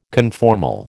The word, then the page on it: conformal: American English